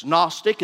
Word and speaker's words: Gnostic: Gnostic is spelled with a silent G at the beginning. You note takers would write it down as G-N-O-S-T-I-C.